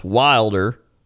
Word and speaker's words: Wilder: Pronounced Wilder. Like, this Bigfoot is wilder than a sheep.